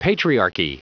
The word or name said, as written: patriarchy